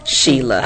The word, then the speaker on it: Sheela: All right, so we are picking back up with our conversation with Sheila Marshall of Tequila Sheila. – Uh-huh. – I love your name. – Thank you. It's spelled differently, that S-H-E-E-L-A, right. Sheila.